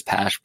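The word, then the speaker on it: Pache: I think it's Pash, pronounced Pash, but Inciarte is a major target if that playing time opens up, which I think it will.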